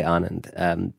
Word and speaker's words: Anand: The train was not even at that station, so he couldn't say Anand. So I said, rhymes with Amund.